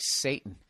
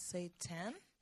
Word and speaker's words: seitan: That looks like Satan. It's a Chinese-American dish. – Seitan? – Thanks. Thanks for having me. No, seitan.